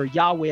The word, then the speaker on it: Yahweh: Hebrew scholars generally favor Yahweh as the most likely pronunciation. So even the JWs affirm that general pronunciation, namely Yahweh.